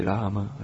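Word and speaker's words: Rama (राम): In Sanskrit pronunciation of Rama, the first A is pronounced long and the second A is pronounced very briefly. So when you chant, you can say Hare Rama, Hare Rama, Rama Rama, Hare Hare.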